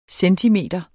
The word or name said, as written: centimeter